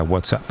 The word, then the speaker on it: WhatsApp: WhatsApp. – No, peor aún, con acento en la primera. WhatsApp, ¿no? Un WhatsApp.